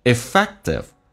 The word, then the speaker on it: effective: effective，首先 effective 有个 k 是不用发音的，那么在美式发音有两个辅音在一起，只用发后面一个，前面如果是一个元音，气流就阻塞一下，就到下面一个辅音，叫 you see effective，他们有说 effective，而且 T 要浊化成 D 这个发音 effective。